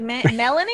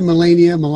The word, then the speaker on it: Melania: Like people are just like, Melanie? – I said Melania.